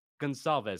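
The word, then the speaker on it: Gonçalves: Gonsalves. So the C is like an S sound.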